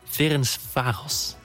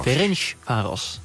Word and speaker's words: Ferencváros: Vojvodina en Ferencváros. – Ferencváros.